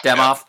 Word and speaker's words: Demoff: As the Rams were making this decision, right, within the structure of the organization, Les Snead, the GM, is it Kevin Demoff? Am I saying his name right? The team president? – Demoff.